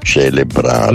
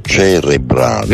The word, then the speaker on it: cerebrale: Sì, non si dice risveglio celebrare. – No, si dice? – Ma risveglio cerebrale.